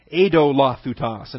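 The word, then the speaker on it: eidolothuta: Idolothutas, right there. E-I-D-O. Some would pronounce it Edo. Edolothutas. And actually, that's probably the better pronunciation.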